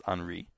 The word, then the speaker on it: Henry: H-E-N-R-Y in English, but in French it's pronounced Henri, that the most likely culprit is a particular artillery officer named Captain Alfred Dreyfus, who at this point is 35 years old.